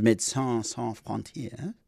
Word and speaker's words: Médecins Sans Frontières: Doctors Without Borders is joining me now, and who's the advocacy officer for Doctors Without Borders, Médecins Sans Frontières, joins me now on the lines.